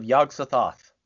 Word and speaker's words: Yog-Sothoth: Okay, so you've got to help us out here by helping me with the title, which is Tales of Yog-Sothoth.